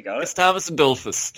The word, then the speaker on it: Gustavus Adolphus: Astolphus Godulphus, or how do you say his name? How does his name go? Gustavus Adolphus? – Yeah, you nearly got it. – Gustavus Adolphus.